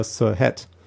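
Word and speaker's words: SourceHut: sondern über SirHat. Ich habe mich überzeugt, das ist die richtige Aussprache, das ist unfassbar.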